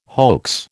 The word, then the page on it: hoax: American English